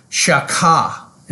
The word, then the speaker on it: shachah: The next one, the next Hebrew word we're going to look at is the word shaka, and that's my southern pronunciation for shachah, and it simply means to bow down.